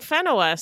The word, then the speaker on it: Whenua: Well, I listened to a lot of pronunciations when I went through this, and the lady was saying Whenua, so that's what I put.